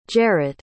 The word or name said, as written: jarrett